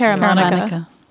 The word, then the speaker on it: Caramanica: I don't know what John Caramanica, is that how you pronounce it? – I think it's Caramanica.